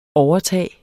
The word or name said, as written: OVERTAGETS